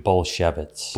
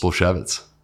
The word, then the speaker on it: Bulsiewicz: Bulsiewicz. – Bulsiewicz.